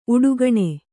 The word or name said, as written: ಉಡುಗಣ